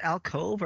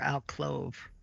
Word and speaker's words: alcove: Is it alcove or alclove? I never really knew how to pronounce that. – Alcove? I don't think there's an L.